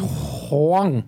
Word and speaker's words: Whang: And you can find her on Twitter at Suzanne Wong, and that's spelled Suzanne W-H-A-N-G. Suzanne Wong. And so Huang. – That's right. – Kind of like that's how Stewie would pronounce it on Family Guy.